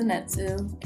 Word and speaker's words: Zenitsu: Alright, so episode 11, we meet Zenitsu or whatever. I don't know how to say his name. – Yeah, his name is Zenitsu.